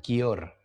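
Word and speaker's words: Győr: Yo lo pronuncio como Gior, pero seguramente aquí me van a ver feo diciendo así no se pronuncia, pero yo diría Gior, G-Y-O-R. – Está en Gior donde va a entrevistar, allá en territorio húngaro, a Efraín Juárez, el técnico mexicano.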